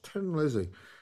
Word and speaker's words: Thin Lizzy: And he's famous in the, you know, that song by Tin Lizzy, or Thin Lizzy. It's spelled that, but it's pronounced Tin Lizzy because they're Irish.